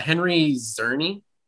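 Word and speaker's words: Henry Czerny: Henry Zerny is how you pronounce him, which I was really excited to find out.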